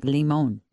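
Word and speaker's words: limón: Also, like in foreign languages, it's like limon. – Well, you're probably right. – So say it like that, Jeff. – I think they try limon. To make it sound limon. – Fancy. – Limon. – Limon. – All right, I stand corrected.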